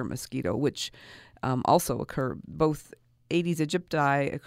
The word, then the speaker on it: Aedes aegypti: Aedes aegypti mosquitoes, which is one of the carriers for Zika? – Currently, that is the main vector, okay.